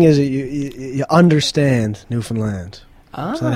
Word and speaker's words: Newfoundland: There's a special way of saying it, isn't it? – Yeah. Newfoundland.